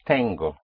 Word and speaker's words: tengo: In Spanish is tengo. Tengo. It's almost like the tango which one dances in Argentina. But it is spelled with T-E-N-G-O, tango.